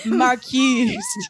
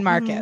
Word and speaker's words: Marquised: Wait, what was the one that we were mispronouncing? – Oh my God, never been marquised. We're like, what a fucking stupid title. What the fuck were they thinking? We're like, been Marcus.